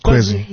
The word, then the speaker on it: Kwezi: So how do you come up with is it Kwezi? Am I saying it right? – That's right. That's Kwezi.